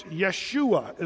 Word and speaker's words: Yeshua: Did you know the Hebrew people didn't even have a J sound in their pronunciation? So the J was always a Y in the Hebrew. So it's Yeshua is the way they would say it.